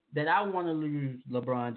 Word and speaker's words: LeBron: LeBron James.